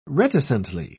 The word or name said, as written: reticently